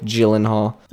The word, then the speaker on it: Gyllenhaal: Jake Gyllenhaal, I believe. – Oh, yeah. – Yeah, this... – Do you say Gyllenhaal or Gyllenhaal? How do you say Gyllenhaal.